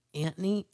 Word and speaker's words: Antony: Now, do we say Antony? Is that how we pronounce that? – Antony, I guess. Antony? – I don't know. There's no H there.